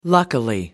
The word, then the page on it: luckily: American English